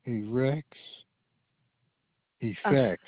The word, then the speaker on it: Erex Effects: How do you pronounce that? – Erex Effects Male Supplement. – Erex Effects. – Like FX.